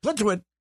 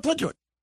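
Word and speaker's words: Plitzuweit: If you say Plitzwit real fast, you can't mispronounce it. Well, Plitzwit.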